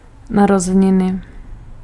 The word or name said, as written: födelsedag